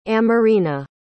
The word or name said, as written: amorina